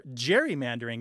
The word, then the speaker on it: gerrymandering: And one of the participants says, "You are constantly gerrymandering the state," or gerrymandering, as I maintain the word should be pronounced, the G being soft.